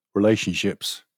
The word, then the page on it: relationships: British English